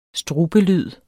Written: strubelyd

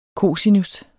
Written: COSINUS

